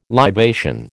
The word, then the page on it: libation: American English